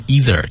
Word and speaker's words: either: 这个 either 当然也可以读成 either, 就像您刚才读的哈。 – 嗯。 – Either 都是可以的。它的拼写是 E-I-T-H-E-R。